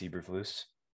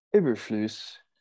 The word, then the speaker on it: Eberflus: I believe it's Eberflus. – Eberflus.